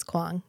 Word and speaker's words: Kuang: Am I pronouncing that right? – I think it's Kuang. Yeah.